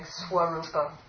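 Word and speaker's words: svarūpa: But if it's in a conjunct consonant, except for a semi-vowel, it will get the pronunciation of wa, like swarupa, right?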